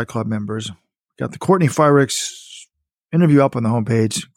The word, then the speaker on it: Frerichs: Frerichs, Courtney Frerichs. There's no Y in that name.